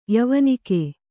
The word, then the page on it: ಯವಾನಿಕೆ: kannada